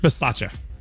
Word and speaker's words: Bisaccia: Rich Bisaccia.